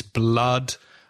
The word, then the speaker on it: blood: So yeah, it's blood and other words with double O that have the A sound.